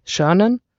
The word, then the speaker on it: Chernin: I pronounce him wrong, do I? Chernin? – Oh, Chernin, yeah.